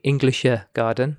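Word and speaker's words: Englischer Garten: We had a long weekend in Munich, and in the Englischer Garden, which is the English garden. – Oh. – Really bad pronunciation. – No, that's very good.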